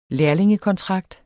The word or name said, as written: lærlingekontrakt